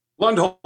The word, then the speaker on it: Lundholm: Lundholm.